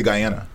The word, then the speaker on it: Guyana: I say Guyana. – Okay, Guyana. But is that because it's an American Guyana? – Nah. Because if I said it in Guyanese, I'd be like Guyana.